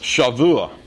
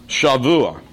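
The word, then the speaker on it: Shavua (שבוע): It is pronounced Shavua. Shavua.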